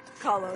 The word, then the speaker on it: Kahlo: Kahlo, is that how it's pronounced? – Kahlo.